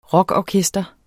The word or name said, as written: rockorkester